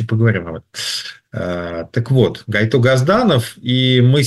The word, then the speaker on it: Гайто Газданов: ну, правильно так его, да, произносим имя, Гайто Газданов, Георгий Иванович Газданов.